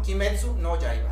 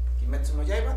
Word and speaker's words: Kimetsu no Yaiba: Kimetsu, no, Kimetsu no Yaiba. Ah, ok, va. Kimetsu no Yaiba, Demon Slayer, Cazadores de Demonios.